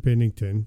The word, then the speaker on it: Binnington: Jordan Binnington.